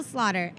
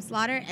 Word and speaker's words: Slaughter: Shanelle Slaughter, S-H-A-N-E-L-L-E. Last name Slaughter, S-L-A-U-G-H-T-E-R.